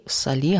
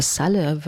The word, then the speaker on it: Saleh: Saleh habe ich früher immer gesagt, aber ich habe gelernt, Dua Saleh wird sie ausgesprochen.